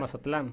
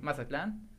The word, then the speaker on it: Mazatlán: Por ejemplo, en el, estado, en el estadio de Mazatlán eh, creo que no se, se pronuncia así, pero nunca lo he sabido pronunciar. Mazatlán. Mazatlán. Clan. Es que yo lo digo con clan.